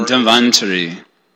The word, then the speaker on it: Dhanvantari: And then, is it pronounced Dhanvantari or? – Dhanvantari. – Dhanvantari. Dhanvantari. I always heard it that pronounced that way. I stand corrected.